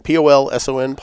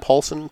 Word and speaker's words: Polson: So I, on the other hand, I'm going to list off some of the ones that uh, our friend uh, Robert Polson. I hope I'm saying that right. P O L S O N Polson Polson. – I guess so.